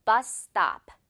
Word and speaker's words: bus stop: 이렇게 중간에 S는 한 번만 발음해주면 되죠. 듣고 따라해 보세요. 버스 스탑. Very good. One more time. Bus stop.